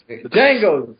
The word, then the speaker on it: Django: Django, D is silent.